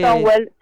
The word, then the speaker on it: Stonewall: Me nombraste recién Stonewall, no sé si se pronuncia así. ¿Qué Stonewall, sí, Stonewall.